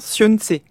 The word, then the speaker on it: Xunzi: et qui s'appelle, j'espère que je prononce bien, Sun Tzu. Voilà, qui s'écrit Xunzi en français, donc voilà. Effectivement les prononciations sont très différentes, et on y retrouve la métaphore de l'habitation que vous employez à l'instant.